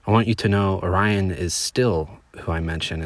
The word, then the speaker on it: Orion: You know that Orion is pronounced O-Ri-on.